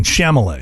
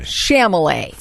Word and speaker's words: Chalamet: I'm embarrassed to admit this, but I just figured out that I have been pronouncing the actor Timothy Chalamet wrong. – No, you've been saying Chalamet. – Chalamet. – For like 2 years. – Yes. And very confidently, I'd be like, it's Timothy Chalamet.